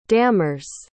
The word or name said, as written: dammers